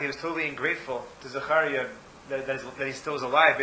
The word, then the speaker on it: Zechariah: Could you phonetically spell Zechariah so I could... – Zechariah, Z-E, like Zechariah. Z-E-C-H-A-R-I-A. – Okay, Zechariah.